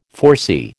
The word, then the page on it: foresee: American English